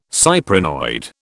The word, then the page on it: cyprinoid: British English